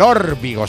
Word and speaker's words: Órbigo: Del Órbigo, sí, lo he dicho bien.